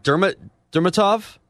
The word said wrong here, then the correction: Dimitrov: Dimitrov. But I know who you're talking about. – Dimitrov. – Yep. – It's Dimitri, isn't it? – Dimitrov. – No, there's an off in there as well.